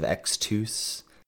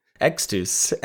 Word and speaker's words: Extus: You love to make the US sound oos, like Rise of Xtus.